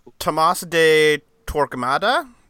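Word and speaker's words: Tomás de Torquemada: Next one is Tomas the Turgemala. – Can any of us pronounce him? – No. – Tomas de Torquemada? – Yes.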